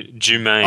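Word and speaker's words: Jumayn: Jermaine, I think it is. – No, there's no R in it. – No, Jumaine. – Oh, Jumaine.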